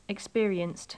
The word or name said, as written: experienced